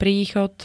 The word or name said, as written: príchod